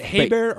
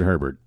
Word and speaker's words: Herbert: Is it Hebert or Herbert? – I think it's Herbert. – Herbert? – I think so. – I think he does pronounce it Herbert.